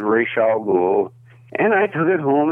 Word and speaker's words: Ra's al Ghul: My daughter asked a professor at UCLA that question about 20 years ago, and I think what she got was Ra's al Ghul.